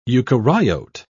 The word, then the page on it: eukaryote: British English